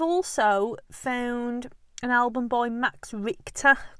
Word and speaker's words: Richter: But if you want to look him up, Max Richter, and it's spelled Max and then R-I-C-H-T-E-R.